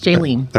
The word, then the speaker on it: Jaylene: Yes, Jaylene. – Okay, good. I was going to say Jolene, but that you've probably heard that before.